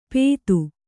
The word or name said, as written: ಪೇತು